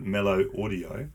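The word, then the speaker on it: Melo Audio: Big thank you to the guys at Mellow Audio. – It does look cool, eh? – On Mellow? How would you pronounce that? – Mellow? Sure. – Mellow Audio? M-E-L-O Audio.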